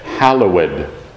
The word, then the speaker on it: hallowed: And then you could all say, hallowed. And you never pronounce the ED on the ends of words except there. Hallowed be thy name.